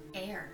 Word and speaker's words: Eyre: Eyre, that's how you say it.